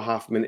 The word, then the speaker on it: Hoffman: Instagram is Noah Hoffman Airbnb.